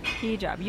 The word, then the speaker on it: hijab: It's hijab. Is that what you said? – Hijab. – Hijab. You were right. I said it wrong. – Is the accent on the... No, the hyphen's on the job.